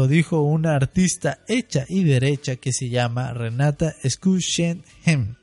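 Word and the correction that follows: Renata Schussheim: Y si no lo pronuncié bien, Lucy, por favor, ayúdanos. – Se pronuncia Renata Chusheim, así se pronuncia jazz. – Gracias, exacto, así se pronuncia.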